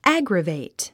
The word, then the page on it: aggravate: ǽɡrəvèit; アァグラベェイト